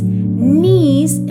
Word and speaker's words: knees: Knees se escribe K-N-E-E-S. ¿Recuerda esa palabrita? Otra cosa para recordar, amiguito o amiguita, es que cuando tienes la K y la N, la K no suena. Por eso decimos knees. Y le alabaron a Dios. ¿Cómo se dice alabar? Se dice worship. W-O-R-S-H-I-P.